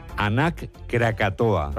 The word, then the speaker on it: Anak Krakatoa: Nos vamos a Indonesia, donde la erupción del volcán, vamos a ver si lo decimos bien, Anak Krakatoa. – ¿Lo he dicho bien? Sí. Sí, parece euskera, pero es indonesio.